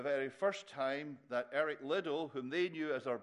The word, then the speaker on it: Liddell: This morning, Jim told the story of Eric Liddell. That's how I would pronounce the name. I know he pronounced it with a different stress, a different emphasis.